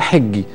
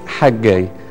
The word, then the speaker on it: حجاي: اسمه حجاي، وهو إحنا أحيانا نغلط في الاسم نقول حجي، لكن هو النطق بتاعه الأصلي حجاي، وده الاسم معناها عيد، اللي هي جاية من الحج، ده عيد.